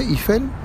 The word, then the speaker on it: Eiffel: Se dice Eiffel.